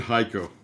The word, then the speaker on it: Hico: One in uh, Hico, Texas, and hey, so they pronounce it Hico. Hico, Hico, Texas, and one in Pueblo, Colorado.